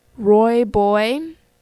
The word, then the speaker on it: rooibos: Rooibos tea, you spell it as R-O-O-I-B-O-S, rooibos tea.